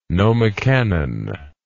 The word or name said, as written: nomocanon